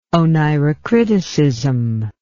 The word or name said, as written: oneirocriticism